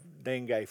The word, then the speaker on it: dengue: And when we got there, they told us prior to going that they had a great outbreak of dengue fever. That's not spelled D-I-N-G-Y, but it's spelled D-E-N-G-U-E.